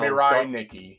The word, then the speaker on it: Mirai Nikki: Mira Nikki. – Oh, Mira Nikki. Yes. And you were telling me how Mirai Nikki.